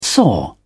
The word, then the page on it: soar: British English